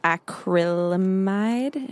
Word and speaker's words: acrylamide: So the specific chemical that they were talking about, I think it was acrylamide.